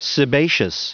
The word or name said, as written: sebaceous